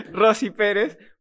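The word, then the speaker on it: Rosie Perez: Rosy Pérez por...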